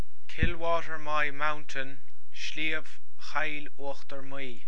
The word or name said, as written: Sliabh Chill Uachtar Maí